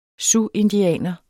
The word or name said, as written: siouxindianer